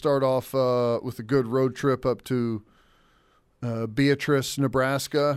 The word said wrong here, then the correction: Beatrice: Well, someone says it's not pronounced like that on the text line. Here, there's the text right there. See if you can get that one. – Be at Trice. – It's a Nebraska thing, is what the text says.